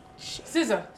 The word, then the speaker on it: SZA: SZA? Is that that's how you say it? SZA? Is that wow. – Yeah. – SZA.